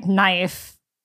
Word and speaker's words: knife: It's like how we can't explain why knife starts with the letter K. Exactly. And we don't pronounce it.